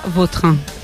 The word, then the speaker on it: Vautrin: Es que a mí me suena mejor Bauterín. Es como... – De más fuerza, ¿no? – Más fuerza, sí, más pronunciación. – Sí, elegante. – La original francesa, Vautrin.